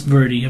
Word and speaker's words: Verdi: Apparently as well, the soundtrack is composed of classical music, including Verdi, is that how you say it? And Tchaikovsky? – Verdi? Vivaldi. Can't see from here. – That says Verdi. – That's Verdi, apparently.